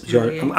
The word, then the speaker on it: Georges Méliès: Yeah, I would say it was Georges Méliès.